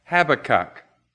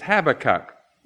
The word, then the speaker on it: Habakkuk: And I don't know how you pronounce in America Habakkuk, but it's Habakkuk in English. I think I've heard it pronounced Habakkuk, Habakkuk.